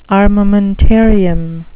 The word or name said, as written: armamentarium